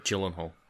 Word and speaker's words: Gyllenhaal: Jake Gyllenhaal. Holy shit, I'm watching him going... – Gyllenhaal. – Yeah, Gyllenhaal, whatever his fucking name is. – It's a soft G. Like... – Hey. – The opposite of GIF.